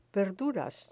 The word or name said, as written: verduras